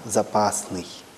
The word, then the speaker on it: запасный: Поэтому запасной, но если мы видим в автобусе надпись «запасный», то на «ы» мы ударение не должны помещать. Мы его должны помещать на «а» – «запасный».